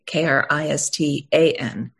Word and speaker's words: Kristan: And Kristan is spelled with an A, Kristan.